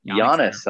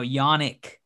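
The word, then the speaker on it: Jannik: Giannis, huh? – No, Yannick, K with a K, and it's a soft J too.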